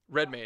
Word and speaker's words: Redmayne: Redmayne.